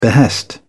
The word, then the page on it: behest: British English